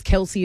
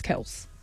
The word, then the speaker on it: Kelce: And then the last one people always get wrong is Travis Kelce is actually Travis Kelce.